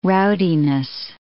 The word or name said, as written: rowdiness